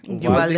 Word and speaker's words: Uvalde: I don't know if I'm pronouncing it right. Uvalde? – Uvalde, yeah.